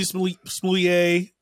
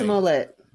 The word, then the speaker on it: Smollett: Please just search uh, you know, Jesse Smoulier. Um th- th- that's not even how do you say his name for real? – What's his real name? Smollett.